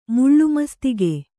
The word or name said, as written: ಮುಳ್ಳುಮಸ್ತಿಗೆ